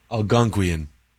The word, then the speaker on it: Algonquian: It's a playful compound of the phrase, you don't see them, derivative of Native American pidgin English or Algonquin influence. – Algonquin? – Yeah. Algonquin. Is that Algonquin? – Algonquian. – Please use that in a sentence. – The Algonquians speak Algonquian.